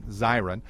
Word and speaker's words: Zirin: Is that how I pronounce it, David Zirin? – Yep, like a fire siren. – Like a siren, Zirin.